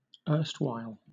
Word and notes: erstwhile: British English

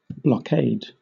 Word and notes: blockade: British English

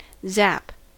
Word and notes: zap: American English

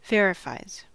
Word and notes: verifies: American English

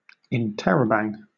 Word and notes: interrobang: British English

